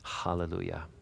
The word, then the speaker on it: Hallelujah: And you can pronounce it with or without an H. People do that, but it's more proper to pronounce it with an H. Hallelujah.